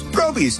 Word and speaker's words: Groby's: Google up Groby's for more specials. Groby's, where the accent is on grow.